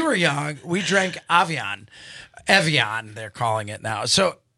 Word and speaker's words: Evian: Anastasia, do you remember Avion? Have you seen Avion? – Evian.